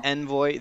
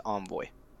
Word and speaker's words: envoy: The British pronunciation is Envoy. The American pronunciation is Envoy.